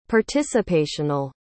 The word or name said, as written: participational